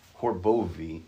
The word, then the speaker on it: Horbowy: My name is Benjamin Alexander Thaddeus John Horbowy. My name, Horbowy, H O R B O W Y, is pronounced with a V, two W, a W with, the picture is two V's.